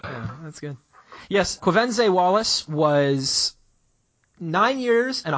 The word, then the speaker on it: Quvenzhané: Her name is pronounced Quvenzhané. – Quvenzhané, That is an excellent name.